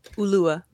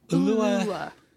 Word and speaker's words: Ulua: How do you say your character's name? – Ulua. – Ulua. – Mm-hmm. Accent on the Lou.